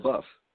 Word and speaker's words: LaBeouf: But we got, the first official trailer for, this was Shia LaBeouf's, LaBeouf? How do you pronounce it? LaBeouf? – LaBeouf. – Okay.